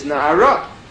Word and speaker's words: Nahara: Nar, it's spelled Nun Ayin Resh, so it's really, it's written Nar, but it's a Kri and Ksiv. The Kri is Nahara.